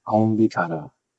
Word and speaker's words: Konbikara: The second word is Konbikara.